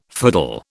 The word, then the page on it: fiddle: British English